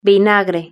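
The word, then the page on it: vinagre: Spanish (Spain)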